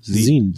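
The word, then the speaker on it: Zine: Ich weiß nie, wie spricht man aus? Sein? Sin? Die coole Abkürzung von Magazine? Sin. Sin.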